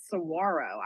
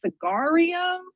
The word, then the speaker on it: Saguaro: I didn't even know how to pronounce Saguaro. I was like, Sagario?